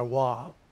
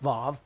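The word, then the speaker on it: Vav (וָו): You brought out Vav or Wav. You find it W-A-W, but it's pronounced Vav in the Hebrew.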